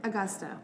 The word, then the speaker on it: Agosta: Agosta.